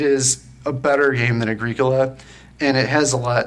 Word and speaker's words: Agricola: It's Agricola. It's like the pronunciation. – It's like the cough drop with an A and G in front of it. That's how I remember.